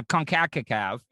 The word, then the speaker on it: CONCACAF: That's how you pronounce it. I always wonder how you said that. – Yeah, CONCACAF.